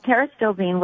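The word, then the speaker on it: pterostilbene: Okay, well, Terastilbene, which is, by the way, it's spelled completely different than it sounds and than it's pronounced. It actually starts with a P. So it's P-T-E-R-O-S-T-I-L-B-E-N-E.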